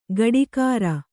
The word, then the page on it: ಕಾಡೀಖಾರ: kāḍīkhāra